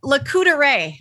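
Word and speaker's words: Lac Courte Oreilles: Well, but this doesn't look like Lacouderay. It looks like Lac Court Orelis. – That's what I was going to say. Lac Court, because I'm from Fond du Lac and Lac spelled the same way. Let's say it's French, isn't it? Lac Court.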